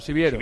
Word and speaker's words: Siviero: Que Siviera era el entrenador, ¿no? Que tenía el Hércules hace poquito. – Siviero. – Ah, Siviero.